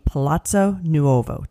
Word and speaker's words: Palazzo Nuovo: Also right next door, and this kind of goes to the museums that we talked about, is the Palazzo Nuovo. Did I say that right? – Yes, you did say that right. Well done.